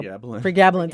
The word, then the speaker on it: Pregabalin: Pregabalin.